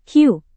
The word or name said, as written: hiu